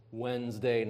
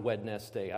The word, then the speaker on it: Wednesday: And it's pronounced Wednesday, not Wednesday.